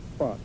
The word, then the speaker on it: pho: Careful how you say it. Pho, P-H-O, but it's P-H-O spelled pho.